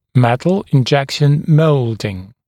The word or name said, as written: metal injection molding MIM